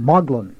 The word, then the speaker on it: Magdalen: And he's a regular fellow at not Magdalen, how do you pronounce it? – Magdalen.